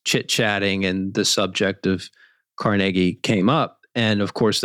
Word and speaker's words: Carnegie: It is Carnegie, and you're correct about that. So, you know, he came from Dunfermline, Scotland, and that was the pronunciation.